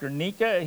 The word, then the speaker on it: Guernica: It's called Guernica, I believe, is how it's pronounced, Guernica.